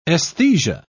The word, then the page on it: esthesia: British English